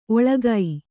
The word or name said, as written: ಒಳ್ಗು